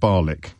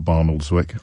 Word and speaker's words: Barnoldswick: It's pronounced Barlick. Of course it is, not Barnoldswick.